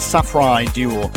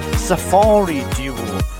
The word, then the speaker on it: Safri Duo: A lot of people misspelled it and mispronounced it, pronouncing it as Safari Duo. Safri is spelled S-A-F-R-I, and a lot of people were pronouncing it as Safari as S-A-F-A-R-I. So there you go, you know the proper pronunciation of it.